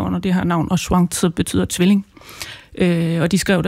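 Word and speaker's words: Shuangzi (双子): Nu lagde jeg mærke til, at du udtalte navnet på en anden måde end mig, og helt sikkert mere korrekt. Men forfatteren, den taiwanesiske Yang Shuangzi, sagde du det? – Shuangzi vil jeg udtale det på kinesisk.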